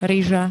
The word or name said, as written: ryža